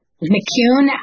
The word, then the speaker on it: McCoon: So McCoon is a name I married into. It's Scottish. It rhymes with tune.